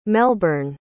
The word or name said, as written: melbyrne